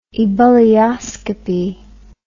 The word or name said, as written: ebullioscopy